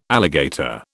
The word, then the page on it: alligator: British English